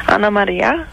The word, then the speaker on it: Anna Maria: Ana Maria. – Right. – Right. Ana Maria. – Right. So what's wrong with that? – That's what I'm asking. – She says Ana Maria, Anna Maria.